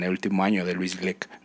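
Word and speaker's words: Glück: Y estuve leyendo poemas de Luis, de Luis Gluck, Gleck, que se pronuncia así, Gleck.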